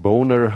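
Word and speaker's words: Boehner: und dann siehst du so deutsche Namen wie Böhner oder das ist Boner heißt der ausgesprochen, und alle möglichen Müller und alles Deutsche.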